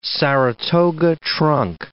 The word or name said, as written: Saratoga trunk